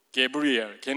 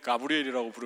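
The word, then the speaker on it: Gabriel: 저는 개인적으로 게브리엘, 걔는 가브리엘이라고 부르면은 또 되게 화를 내는 애예요. 게브리엘 이렇게 그렇게 발음을 해야 된대요.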